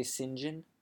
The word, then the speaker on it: St. John: "It's pronounced St. John." – "St. John?" – "Yeah." – "Not St. John?" – "No." – "Why is it not St. John?" "It's written S-T, period, John."